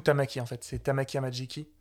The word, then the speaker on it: Amajiki: Amai Jiki Tamaki. – Amajiki, hein, je crois que c'est Amajiki.